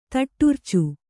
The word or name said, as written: ತೊಡರ್ಚು